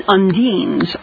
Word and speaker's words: undines: And undines are water. – I've been saying it wrong. Undines are water. Thank you.